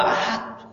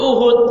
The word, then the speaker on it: Uhud: Oleh karena itu, terus batu ditetapkanlah gua suatu badrin bitawaturi wa anaha qabla Uhud, bukan qabla Ahad. Masa qabla Ahad artinya apa? Sebelum satu. Ya Uhud, ya perang Uhud maksudnya.